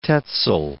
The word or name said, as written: Tezel